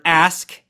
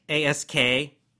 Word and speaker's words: ask: The first word, ask, A S K, most people can pronounce that fairly well, but ask. Again, you want to just get that little k sound at the end. Make sure you get the K. Don't say S, S, right? It's too weak. You're, you're missing the K. It sounds strange. So you need the K sound at the end. Ask.